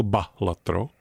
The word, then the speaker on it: balatro: Ve starověkém Římě balatro, vyslován jako balatro, byl balatro profesionální šašek nebo bafún takzvaný.